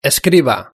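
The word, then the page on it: escriba: Spain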